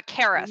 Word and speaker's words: Kareis: Uh, you got close. Uh, Kareis, mm. like carrots Kareis. without the T's.